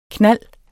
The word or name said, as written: knald